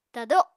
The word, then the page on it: tado: Apatani